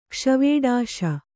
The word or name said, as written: ಕ್ಷ್ವೇಡಾಶ